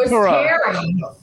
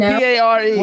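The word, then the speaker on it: Paré: It's not Perot. – His name is Michael Pare. – It's P A R E. – Well, if you speak French, it's Perot. – It's Pare, is how you pronounce it.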